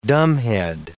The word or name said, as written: dumbhead